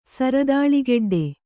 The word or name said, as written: ಸರದಾಳಿಗೆಡ್ಡೆ